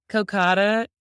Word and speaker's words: Kokada: Kokata, it's a tricky name for a lot of people to say. It's spelled K-O-K-A-D-A.